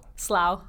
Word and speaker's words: Slough: Is it pronounced Slough? – Slough. – Slough. Yeah.